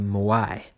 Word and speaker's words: moai: So in Okinawa, they create this thing called a moai. That's spelled M-O-A-I.